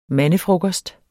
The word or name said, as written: mandefrokost